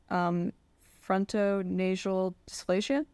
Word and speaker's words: frontonasal dysplasia: Matt was born with, and tell me if I'm pronouncing this wrong, frontonasal dysplasia? – That's right.